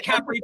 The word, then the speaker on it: Capri: And this one is set in Capri. So apparently that's how you say, you don't say Capri. – I've always said Capri my whole life. – Capri. – Are they Capri pants? – First syllable, Capri.